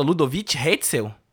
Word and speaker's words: Ludovic Hetzel: Como é que fala? Ludovic Hetzel? Isso, Ludovic Ludo Hetzel.